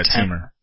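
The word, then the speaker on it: Temur: How do you pronounce that? Teamer? – Yeah, Teamer. – Is it Teamer? – I believe so.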